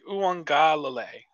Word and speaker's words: Uiagalelei: Is it Uiagalelei? – It's like Uiagalelei. – Yeah. That's a tough one.